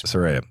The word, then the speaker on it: Saraya: I'm saying it right, right? I just want to make sure that Saraya. I'm getting it. Mm-hmm. – Yes, Saraya, good.